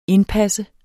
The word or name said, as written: indpas